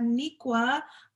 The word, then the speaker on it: Niqua: I have Niqua from Financially Winning on Instagram.